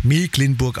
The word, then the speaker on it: Mecklenburg: Übrigens für alle Skeptiker, die immer noch sagen, wieso Mecklenburg, das wird doch aber mit CK geschrieben. Gleich 7:10 erklärt uns Björn Berg vom Kreisagrarmuseum Dorf Mecklenburg im Landkreis Nordwestmecklenburg, warum es dennoch Mecklenburg ausgesprochen wird.